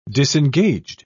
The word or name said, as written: disengaged